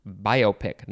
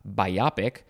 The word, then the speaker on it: biopic: That's how it is pronounced, biopic, not biopic. It is biopic, B-I-O-P-I-C.